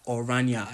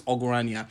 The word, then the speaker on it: Ogranya: First of all, I'm upset because I thought his name this whole time, I've been pronouncing it Oranya. I thought the G was silent, like lasagna. You know what I'm saying? But apparently it's Ogranya.